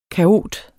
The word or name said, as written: kaot